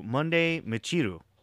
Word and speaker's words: Monday Michiru: But for right now, I would like to introduce you all to Monday Michiru.